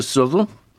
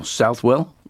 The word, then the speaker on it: Southwell: Next one is Gate to Southwell, or Southwell, as it's spelled.